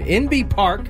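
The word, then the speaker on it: Inbee: NB Park and Justin Rose won gold medals in 2016 when which sport returned to the Summer Olympics after a 112-year absence? – Golf.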